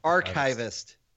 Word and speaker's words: archivist: Like an archive? You know what an archive is? – Yeah, I know what an archive is. Archivist. I'm pretty sure if you look in the dictionary, you'll see how it's pronounced. – I'm sure you will. – You can say archive. – Yeah, sure. – This isn't your chives. This is archives.